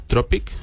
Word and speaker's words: Anthropic: Anthropic se escribe con A-N-T-H, Anthropic, como suena en inglés.